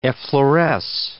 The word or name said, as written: effloresce